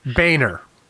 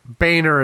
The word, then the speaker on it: Boehner: We got corrected on pronouncing Bonner boner. What was the Boehner. – Boehner. – Boehner is the correct pronunciation.